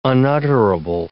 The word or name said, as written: unutterable